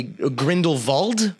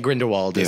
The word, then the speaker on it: Grindelwald: Well, okay, so if you want to pronounce it properly, it would most likely be Grindelwald. Yeah. But all the Americans in the film say Grindelwald, so I think...